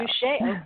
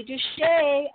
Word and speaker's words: Dushey: Dushey. Okay. Got to put that somewhere. Okay. All right, Joy Dushey.